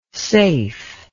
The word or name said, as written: seif